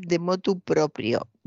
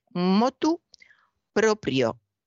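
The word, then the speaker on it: motu proprio: Lo corregía bien porque no se debe decir de motu propio, perdón, de motu propio. Se debe decir motu propio, sin la D, sin la preposición inicial propio, con PR en la segunda sílaba, motu propio.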